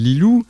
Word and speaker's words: LEE.LOO: Alors, à propos de chouette, euh, justement, le, projet que tu veux un petit peu euh, lancer qui s'appelle Lilou qui s'écrit L-E-E.